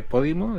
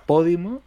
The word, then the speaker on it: Podimo: Es una esdrújula. Si fuera Podimo, se tendría que acentuar. Y veo que no se acentúa. – Por eso. – Es Podimo. – No, porque no lleva acento en la I. Es Podimo. Es como podcast. – A ver. – Sí. No, pero bueno, si fuera Podimo, sería Podimo y Po debería tener un acento.